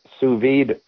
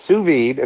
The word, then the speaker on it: sous vide: Are you familiar with sous vide cooking? – I'm not. – So sous vide, and it's spelled S-O-U-S, that's one word, and then V-I-D-E, sous vide.